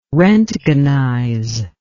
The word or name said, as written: roentgenize